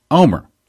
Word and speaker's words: Olmert: On April 6th, Omer, spelling O-L-M-E-R-T, but pronounced Omer, was officially asked by President Moshe Katsav to form a government.